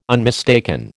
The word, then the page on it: unmistaken: American English